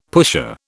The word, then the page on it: pusher: British English